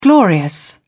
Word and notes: glorious: British English

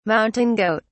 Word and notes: mountain goat: British English

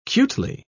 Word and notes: cutely: British English

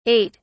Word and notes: 8: British English

